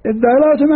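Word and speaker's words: ادّارأتم: طبعا داراتهم الدال المشددة، الدال المشددة، من وين جبنا الدال المشددة؟ الأصل تدارأ، أدغمت التاء في الدال فصارت الدارأ، أساسها تدارأ، ادغم التاء في الدال وهات همزة للنطق بالساكن، شو بصير؟ الدارأ، الدارأتم أن تدافعتم.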